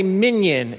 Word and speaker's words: minyan: So what they said is you say it with 10 other people, what is known as a minyan in Hebrew. Not like the show, but pronounced the same.